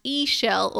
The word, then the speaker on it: Ixchel: So Ischel, it's actually spelled IXCHEL, and it's pronounced Ischel or Ischel, according to the book.